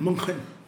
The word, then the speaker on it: München: ¿O cómo se pronuncia, ¿Munich? ¿O eh, München? – München. Un saludo para Enrique Charles que, que se enoja si no lo pronunciamos bien.